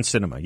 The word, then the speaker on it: Sinema: Is that how you say your name? – Yeah, yes, yes. Excuse me, so Kirsten Sinema, yes.